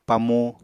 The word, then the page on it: pamu: Apatani